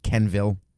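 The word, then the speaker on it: Quenneville: His name is spelled Queenville, but it's pronounced Kenville, I think.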